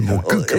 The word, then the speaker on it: Montcuq: Il faut prononcer le cul à la fin. Reportage. – Oui, comme ils disent, on dit un coq, on ne dit pas un co, donc on dit Montcuq.